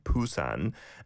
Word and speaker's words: Busan: You can still find it from time to time, spelled P-U-S-A-N, Busan. And a B, I suppose, it's closer to a B, I would say, in terms of the sounds, but it's not a million miles away from a P either, depending on which vowel sound it is paired up with.